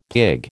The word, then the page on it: pig: American English